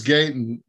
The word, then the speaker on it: Gaten: Gatlin. – It's hard to pronounce. let's see. It is. – He's Gaten Matarazzo.